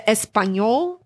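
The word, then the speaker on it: espagnole: The espagnol is, or I don't even know if I'm saying that correctly. – Yeah, you are.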